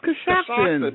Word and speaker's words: Coshocton: I never know it's pronounced Coshocton. – No, it's Coshocton.